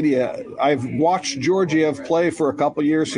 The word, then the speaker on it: Georgiev: Now, this Georgiev, is that how you pronounce that guy's Georgiev? – Yeah, in um, Colorado.